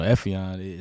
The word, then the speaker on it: Efiong: Yeah, that's why I like my name because on paper you see my name you know, Efiong is spelled with that G on there.